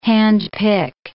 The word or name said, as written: handpick